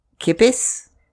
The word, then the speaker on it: kippis: Kippis.